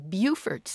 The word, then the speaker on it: Beaufort: Well, we've got a question from Rick in Beaufort, South Carolina. Rick, did I get that pronunciation right? – Yes, ma'am.